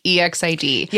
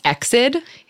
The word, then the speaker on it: EXID: So EXID, and it is pronounced EXID. – Yeah. – Some people think that it's EXID.